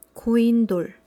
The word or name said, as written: goindol